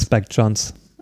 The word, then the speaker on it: Jonze: Spricht man den so aus? – Spike Jonze. – Ah ja, gut.